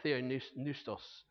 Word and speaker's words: Theopneustos: It's Theopneustos, I think it is. Theopneustos? Yeah, you forget about the P, don't you? That's what you do. Yeah, Theopneustos.